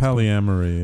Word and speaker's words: polyamory: Polygamy. – Polygamy or polyera, whatever. – Polyamory. – It's polyamory. Yeah.